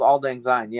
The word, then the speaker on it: Auld Lang Syne: It's called Same Old Lang Sin. I'm not even sure if I'm pronouncing it right. – Oh, Same Old Auld Lang Syne.